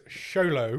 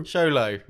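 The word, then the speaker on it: Xolo: Yolo Maraduena. Maraduena. – His name is Sholo. – Sholo. – Maraduena. – There you go.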